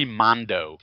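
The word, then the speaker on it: Mondeaux: Henry Mondo, but it's spelled like Cajun, to E-A-U-X at the end.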